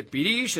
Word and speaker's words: pirix: Pirish. Se dice pirish, es una palabra muy hermosa.